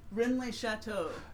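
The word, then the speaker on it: Rennes-le-Château: Rennes-les-Châteaux.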